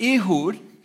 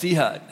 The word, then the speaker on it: Ehud: Now, in Israel, they pronounce it Ehud, but I was raised in southern Illinois. It's Ehud.